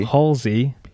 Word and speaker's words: Halsey: a song I haven't stopped listening to is the new one with Yungblud and Halsley. Hol- Halsey. Halsey. Everyone, well, everyone pronounced it wrong.